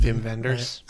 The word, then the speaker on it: Wim Wenders: Vim, because it's a W, it's German. – Vim Wenders. – Right?